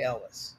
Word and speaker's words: Elwes: And he said in an interview once, It's like Elvis, but with a W. So, going with Cary Elwes.